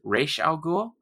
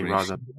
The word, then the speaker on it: Ra's al Ghul: You're right, but it's funny because I guess the official pronunciation is Ra's al Ghul, but people do say Ra's al Ghul a lot.